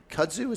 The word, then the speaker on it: Kudzu: All right, so we're going to be smoking the kudzu.